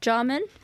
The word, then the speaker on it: Jarman: He's also worked with artists as diverse as the filmmaker Derek Jarman.